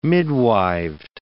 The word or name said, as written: midwived